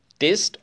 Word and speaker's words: taste: To satisfy the urge of tongue, test. Taste or test? What do you call? How do you pronounce that? – Taste.